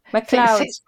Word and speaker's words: McLeod: J'apprécie comment vous prononcez mon nom de famille, McLeod. – C'est bien. – J'entends les Français dire Tati, c'est McLeod. – Ah, j'ai eu la prononciation vraiment à l'anglaise.